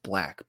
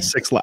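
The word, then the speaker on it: 6lack: Fifth is it's pronounced Black, but with a six at the beginning. – Six lakh.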